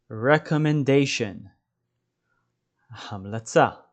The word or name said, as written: recommendation